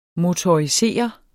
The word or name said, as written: motorisere